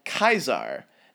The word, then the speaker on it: Caesar: Keep in mind, the modern English way of saying Caesar is not the original Latin pronunciation. As difficult as it is for me to say, Caesar in proper Latin sounds more like Kaiser.